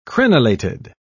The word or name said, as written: crenellated